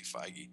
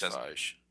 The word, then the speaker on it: Feige: During an interview with Entertainment Weekly, Marvel Studios president Kevin Feige, or whatever, Dante always gets very upset when we mispronounce it. I think it's actually Feige. – I think it's Feige.